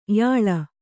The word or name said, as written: ಯಾಳ